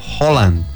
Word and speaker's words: Haaland: ¿Cómo les parece que esto es el dialecto o idioma noruego y el apellido de él no se pronuncia Haaland, sino Holland? – Holland.